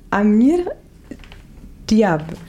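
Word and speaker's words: Amr Diab: Und zwar heißt der Song Tamali Mark von Amir Diab. – Richtig, super ausgesprochen.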